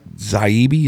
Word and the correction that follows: Taibbi: is that how you say his name? – Taibbi.